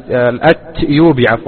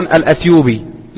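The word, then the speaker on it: الإتيوبي: طرائف من طرائف ما يروى أن شيخنا الشيخ العلامة محمد بن علي آدم الأتيوبي الأتيوبي يكتب في كتاباته وفي كتبه بالتاء، بينما السائد في النطق أن الناس يقولون الأثيوبي له ولغيره. فحصل أن أحد طلبة العلم مر عليه فلما سلم عليه فنطق كان يعرف الشيخ محمد علي آدم الأثيوبي قال الأثيوبي، قال لكن نحن يا شيخ يعني ننطقها هكذا منذ ولدنا. قال أنتم منذ ولدتم وأنتم مخطئون، منذ ولدتم وأنتم مخطئون.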